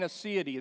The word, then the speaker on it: aseity: Divine aseity, that's A-S-E-I-T-Y, because I knew you wanted to spell it. And so A-S-E-I-T-Y,